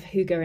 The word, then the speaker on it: hygge: It's spelt H-Y-G-G-E and pronounced hygge, H-O-O-G-A, I guess.